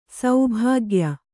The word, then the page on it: ಸೌಭಾಗ್ಯ: saubhāgya